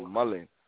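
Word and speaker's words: mullein: Yeah, you had sent me some information about, as it pronounced, Mulan leaf. – The mullet. I think they pronounce it like mullen.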